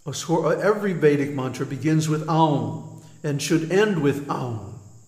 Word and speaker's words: Aum: So anyway, this contains some very deep secrets that the pranava, Aum, don't mispronounce it Om, because first of all, it has three letters, A-U-M. A-U-M. And each one gets a full beat. So, it's not Om, it's A-U-M.